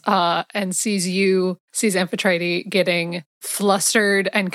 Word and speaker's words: Amphitrite: Again, Amphitrite. – Amphitrite. Okay. – People mispronounce it all the time. It's like an issue, but it's Amphitrite.